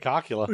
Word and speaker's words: Cocula: I mean, how else do you pronounce that? – It's definitely Cocula.